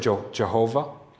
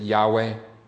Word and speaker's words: Yahweh: In the Hebrew language, four letters YHWH, loosely translated, that we've heard, we've heard Jehovah and we hear Yahweh. We really Yahweh is probably a little bit better because the, there is no J in the Hebrew language, so we pronounce it Yahweh.